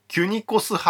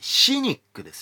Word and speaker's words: cynic: これまたちなみになんですけどキュニコス派って CYNIC って書くんですよ。これ読み方によっては CYNIC ってシニックですよね。これね、シニカルの語源なんですよね、キュニコス派って。確かにディオゲネスほどシニカルって言葉が似合う人はいない気がしますけどね。